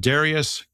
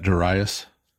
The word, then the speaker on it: Darius: Darius, king of Persia. – You don't want to be British and pronounce that Darius? – Darius, yes.